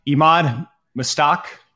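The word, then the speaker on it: Emad Mostaque: All right, Imad Mostak, did I say that right? – Yep.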